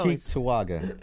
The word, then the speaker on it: Cheektowaga: Fargo Cheektowaga. That's the pronunciation. – It is. – You got it right.